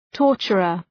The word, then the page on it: torturer: ‘tɔ:rtʃərər